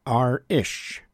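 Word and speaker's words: arís: We heard Planxty's arrangement of a song called The Cliffs of Dunedin, a track that comes from a fine compilation LP titled R-ish. That's spelled A-R-I-S, and that's the Irish word for again or encore.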